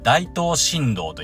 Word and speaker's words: 大東新道: この道を大東新道と言います。大東新道。大東と書いて大東ですね。